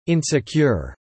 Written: insecure

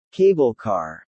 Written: cable car

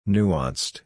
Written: nuanced

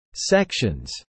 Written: sections

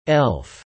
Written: elf

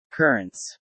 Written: currents

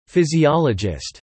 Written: physiologist

